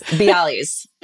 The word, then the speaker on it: bialys: Bialys? How do you say it? – Yes, bialys.